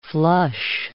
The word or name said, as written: flush